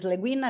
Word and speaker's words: Le Guin: I hope the pronunciation is Le Guin. I mean, I... – Yeah, as far as I'm aware it is, don't worry.